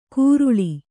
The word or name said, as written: ಗುರುಳಿ